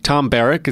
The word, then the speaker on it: Barrack: But Tom Barrack, is that how you say his name? – Yes.